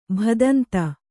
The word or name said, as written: ಭದಂತ